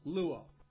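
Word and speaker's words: luo (λύω): Remember the little word luo? L-U, long O, luo.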